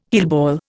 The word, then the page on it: heelball: Indian